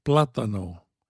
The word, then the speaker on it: plátano: In Spanish, the word plátano has an accent on the first syllable, but in Spanish, the unaccented vowels do not change. You don't say plátano, plátano. You say plátano, plátano, plátano, plátano.